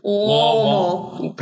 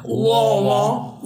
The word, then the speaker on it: Louisville: Louisville. – Perfect. – We're in Louisville.